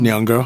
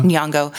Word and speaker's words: Nyong'o: Nyong'o. – Uh-huh. Nyong'o was supposed to be on.